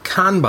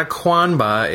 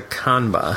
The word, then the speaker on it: Qanba: And notably, I got the Kanba, I believe that's the right pronunciation, spelled Q-A-N-B-A. – There's no U after the Q. There's no U, so it's not a Kwanba, it's a Kanba.